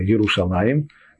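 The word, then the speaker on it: Иерушалаим: Кстати, не будем забывать, что хотя мы произносим слово Иерушалаем в двойственном числе – но пишется-то оно Еру Шалем.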